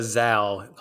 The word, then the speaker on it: Zalle: It's a Zalle, like pal.